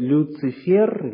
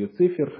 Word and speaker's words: Люцифер: Вот тот, кого называют Люцифер, или Люцифер, разное ударение вы можете встретить, глава ангелов, руководитель небесных хоров, восстал против Бога.